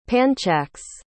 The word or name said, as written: panchax